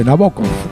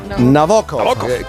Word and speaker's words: Nabokov: Estuve hablando con una amiga rusa el otro día y me dijo cómo se pronuncia correctamente. Nabokov. – Nabokov.